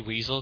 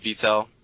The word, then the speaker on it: Wiesel: I don't want to say Weasel, because I think that's wrong, but I have no idea. – I think they pronounce W's as V's, so it's Wiesel.